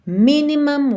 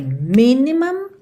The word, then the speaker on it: minimum: O cuidado que você tem que ter é só para esse u não ficar muito u, não é mum, mum, é mam, mam, ok? Então, minimum waste. E essa sílaba que tem o M e o M é a última sílaba. E as sílabas tônicas são as primeiras nessas duas palavras.